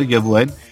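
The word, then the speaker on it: Eguavoen: Il y a Iroha et Egoavuen, je ne sais pas si je prononce bien, peut-être c'est... – Oui, c'est ça, Egoavuen.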